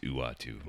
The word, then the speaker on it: Uatu: Let's talk about the Watcher, or as he's really known as, I'm going to mispronounce this, Uatu? Uatu the Watcher? – Okay. – It's U-A-T-U. That's his actual name. – It is pronounced Uatu. – Uatu, yeah.